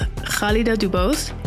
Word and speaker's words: Khalida DuBose: My name is Khalida Dubose.